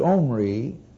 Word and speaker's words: Omri: And those 10 tribes, by the 6th king, have a new capital at Samaria, and the 6th king's name was Omri, pronounced with a harsh aspirate. As it's anglicized, it comes over without the aspirate guttural GH or KH sound in front of it.